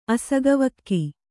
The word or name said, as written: ಅಸಗವಕ್ಕಿ